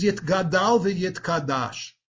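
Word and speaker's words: Yit Gadal v'Yit Kadash (יתגדל ויתקדש): or those people who know Aramaic, pronounce it as Yit Gadal v'Yit Kadash.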